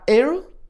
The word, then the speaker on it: Earl: Entonces, ya cuando puede hablar con ella, le dice que se llama Earl. Así como aire, pero con L al último. – Earl.